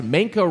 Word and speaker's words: Manka: That roast was sent in from Ian Manka, who writes, Manka rhymes with instant coffee brand Sanka. I didn't know how to say Sanka, so I had to look it up.